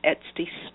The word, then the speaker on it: Etsy: Samantha also has an Etsy store.